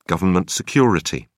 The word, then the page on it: government security: British English